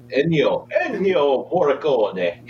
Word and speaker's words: Ennio Morricone: I was going to say before I threw out the rankings, let's not forget a couple weeks ago, we, Ennio Marcione, how do you say it? Ennio, my voice is. – Ennio Morricone. – Well, there we go.